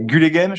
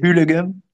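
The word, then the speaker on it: Gullegem: Euh, Gulegem, je sais pas si on prononce comme ça, je crois que c'est Hulegem. Si, euh, Hulegem. c'est un, G euh, flamand, quoi.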